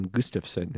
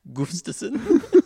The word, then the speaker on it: Gustafson: Not pronounced Gustafson. – I don't know how it's actually. – You're just overpronouncing that name. – We were talking about Dirk Nowitzki earlier. You wanted to lean into it. Gustafson. It's a soft U.